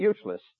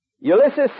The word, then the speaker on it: Ulysses: That's why I didn't know how to pronounce that. I used to call that Useless. You remember Useless Grant? Ulysses said, well, now we've tried everything else, let's start using our brains.